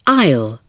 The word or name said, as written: aisle